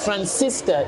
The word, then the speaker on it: Francisca: Is Francisca. It's like San Francisco. – Uh-huh. – Instead of the O, it's an A, but I'll spell it.